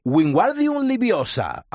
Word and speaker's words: Wingardium Leviosa: Wingardium Leviosa.